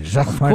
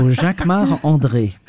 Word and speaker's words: Jacquemart-André: You say it like a Frenchman. – Jacquemart-André. Of course, because I'm French. So Jacquemart-André. – And say it so we can understand it. – Jacquemart-André.